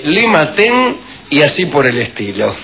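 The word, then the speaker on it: Lima Ten (利瑪竇): Matteo Ricci, que en chino se dice Lima Ten, si usted se llama Matteo Ricci, vamos, clase de chino.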